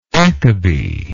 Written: akebi

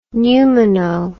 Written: pneumono

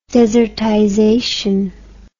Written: desertization

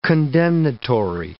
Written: condemnatory

